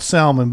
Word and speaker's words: salmon: It's spelled salmon, but it's salmon.